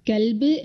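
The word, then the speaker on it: കൽബ് (كلب): അതുപോലെ ക എന്ന അക്ഷരത്തിന് 2 ഉച്ചാരണങ്ങൾ വരുന്നു. 1 ക, 1 ഖ. കൽബ് എന്ന് പറയുമ്പോൾ നായ എന്നർത്ഥം വരുന്നു. എന്നാൽ ഖൽബ് എന്ന് വരുമ്പോൾ അതിന് ഹൃദയം എന്നാണ് അർത്ഥം വരുന്നത്.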